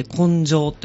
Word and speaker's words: 紺青: あ、そうなんですね、はい、で、九谷五彩っていう九谷焼き、はい、に用いられる象徴するような 5 つの色があって、はい、で、それ赤と黄色と青と紺青と書いて紺青と呼んでいますが、